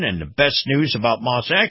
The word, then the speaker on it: Maus: Now, Moss is spelled M-A-U-S, and Acura competes very favorably with other luxury brands.